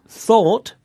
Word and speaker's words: thought: Now, the words thought, spelt T-H-O-U-G-H-T, and ought, spelt O-U-G-H-T, both have the O-U-G-H pattern in them, and it's pronounced all. Thought. Ought. – Thought. Ought. – Mm. – And there are more O-U-G-H verbs with the same pronunciation, like fought, the past of fight, and bought, the past of buy.